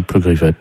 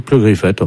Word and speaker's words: Pleugriffet: Comme ça se prononce, hein, Pleugriffet. – Vous pouvez m'appeler, s'il vous plaît ? – Oui, Pleugriffet, en fait. – C'est-à-dire P-R. – P-L. – P-L. – U-G-R-I. – E-2-F-T. Attendez. P-L-E-U. – G-R-I-2-F-R-E-U-T-E.